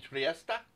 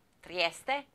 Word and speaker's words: Trieste: Tartini in Trieste. – Trieste.